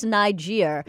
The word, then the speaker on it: Niger: I always had this weird thing about how it's pronounced, and then I heard people pronouncing it. Yeah, it is Niger. – Yeah, Niger. – I always thought it was Niger.